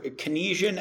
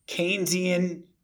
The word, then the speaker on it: Keynesian: And I always say this guy's name wrong, and I've never – Keynesian economics. How do you say this guy's name? – Yeah. It's Keynesian. John Maynard Keynes and Keynesian.